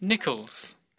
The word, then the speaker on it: Nichols: It's Nichols. – Could you spell that, please? – N-I-C-H-O-L-S.